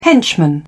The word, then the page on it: henchman: British English